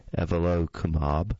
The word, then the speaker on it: evolocumab: Evalacumab, or Repatha, or it's probably pronounced Evalocumab, and it's spelled E-V-O-L-O-C-U-M-A-B.